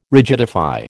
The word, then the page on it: rigidify: American English